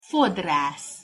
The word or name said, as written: fodrasz